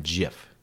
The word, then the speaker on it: GIF: He came out and said it's a soft G, as in GIF.